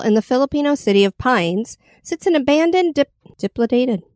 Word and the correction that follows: dilapidated: I totally know dilapidated.